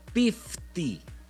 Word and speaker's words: piftie: En Rumania, el guisado tradicional para la Navidad es algo que se llama pifti, con P de Pedro. – Pifti. ¡Órale! – Se escribe piftie.